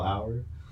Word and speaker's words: hour: I was like, that's how you spell hour? – Yeah. – A-U-R. A-U-R. – That's, it's just... – Hour. – European. – The H is silent. Like lasagna.